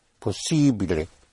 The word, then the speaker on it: possibile: Because the letter I is always pronounced E, possibile. And it is E, spelled E with an accent.